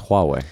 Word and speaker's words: Huawei: Well, how do you say it? – It's Huawei.